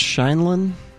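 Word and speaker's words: Sheindlin: I think it's Shinlin. – I think it is, yeah. – Okay, I looked up the pronunciation for this. It says breakdown. Sounds like the word shade or shade with an N.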